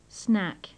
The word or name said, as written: snack